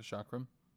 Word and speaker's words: chakram: Do you say chakra? – Yeah. – Yeah, then it's like a chakram. Because it looks... It is spelled almost exactly like chakra, but with an M.